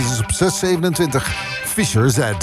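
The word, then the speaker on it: Fischer-Z: Ik zei vroeger ook altijd Fisher Z. Ik weet ook niet waarom. Maar het is Fisher Z.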